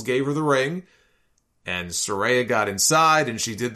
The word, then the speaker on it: Saraya: She is now going by her first name, which I have been mistakenly pronouncing as Saraya, and she corrected everybody on Twitter and said it's Saraya.